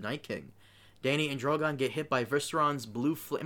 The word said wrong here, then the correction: Viserion: Am I saying his name right? Viseron? – Viserion? – Viserion. I'm sorry.